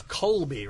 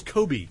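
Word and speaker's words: Cobie: It's Colby, right? – No, it's Kobe. – Kobe? – Like Kobe Bryant, only it's spelled differently. C-O-B-I-E S-M-U-L-D-E-R-S.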